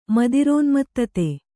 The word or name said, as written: ಮದಿರೋನ್ಮತ್ತತೆ